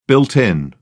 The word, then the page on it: built-in: British English